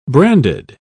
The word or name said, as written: branded